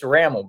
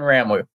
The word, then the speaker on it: Rammel: It's Rammel, Brian Rammel.